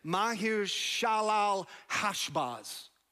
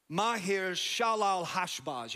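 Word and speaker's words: Maher-shalal-hash-baz: Mahir Shalal Hashbaz. He's one of the sons of the prophet Isaiah. Mahir Shalal Hashbaz.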